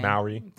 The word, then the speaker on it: Mowry: Mowry.